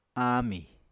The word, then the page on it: áamì: apatani